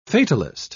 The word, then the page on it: fatalist: British English